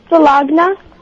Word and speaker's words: Sulagna: Hi, I'm Sulagna and I think the answer is motel hotel. – What's your name again? – Sulagna. – How do you spell that? – S-U-L-A-G-N-A.